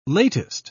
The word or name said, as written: latest